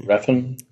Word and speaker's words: Refn: Refn.